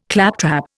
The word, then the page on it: claptrap: Indian